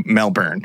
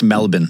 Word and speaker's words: Melbourne: was traveling on a family trip cross-country from the city of Perth, from where they were from, over to Melbourne, which they would have passed through a number of... – It's pronounced Melbourne, actually. – Melbourne. – They'll make fun of you if you say Melbourne.